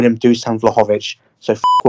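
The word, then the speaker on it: Dušan Vlahović: And Dusan Vlahovic, and yes, I'm going to pronounce him that way, Andy, because I don't give a what Google says.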